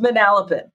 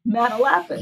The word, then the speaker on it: Manalapan: It's pronounced Manalapan. – Why correct me this early? There's going to be many more mistakes like that. – You sounded silly. Manalapan. – Yeah, that sounds way better.